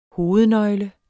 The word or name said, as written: hovednøgle